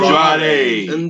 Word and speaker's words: Andrade: The Street Profits versus Andrea and Angel Andrade. – You get his name right. – It's Andrade and Angel Garza.